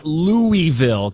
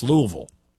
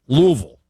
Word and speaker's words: Louisville: So just teaching you today, if I can teach you one thing, it's not Louisville because they hate that. It's Louisville. Louisville. It almost is one syllable.